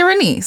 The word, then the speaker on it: Renece: Alicia Renice. So that's AliciaRen as in Nancy E-C-E.